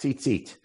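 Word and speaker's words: tzitzit (ציצית): But we pronounce it as if there's a second yud, correct? Tzitzit.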